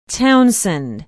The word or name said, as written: townsend